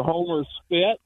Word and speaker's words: Homer Spit: Went out on the Homer Spit, and that's not a mispronunciation. It's called the Homer Spit.